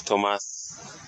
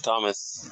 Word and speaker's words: Thomas: You know, it also rhymes with my name because the way I pronounced it when I came here, Thomas, instead of Thomas, because I had a French accent.